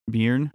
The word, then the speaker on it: Byrne: Rose Byrne, maybe? – Byrne. – Is it Byrne? – Yep. – Yeah, Byrne.